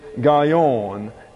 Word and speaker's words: gaon: That's spelled G-A-A-W-A, gaava. The fifth is arrogance. And the word for arrogance is gaion, G-A-O-N.